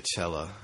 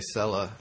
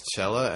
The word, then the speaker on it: Sella: It's Italian, but originally, like, and I think it stopped with my grandparents, they would say cello. That's how, you know, like, the instrument cello, it's pronounced the same way. – Really? – Except with an A at the end.